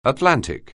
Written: Atlantic

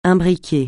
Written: imbriquer

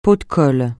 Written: pot de colle

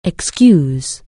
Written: excuse

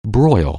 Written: broil

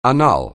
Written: annul

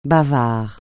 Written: bavard

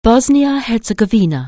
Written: Bosnia-Herzegovina

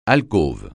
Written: alcôve

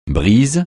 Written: brise

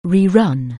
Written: rerun

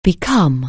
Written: beckon

